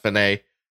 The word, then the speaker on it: fine: And that is referencing Finay at the end of a composition where you have the double bar line and the word F-I-N-E, pronounced Finay, to designate the end of a piece.